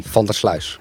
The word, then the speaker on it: van der Sluis: Van der Sluis.